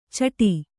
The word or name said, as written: ಚಿಟ್ಟು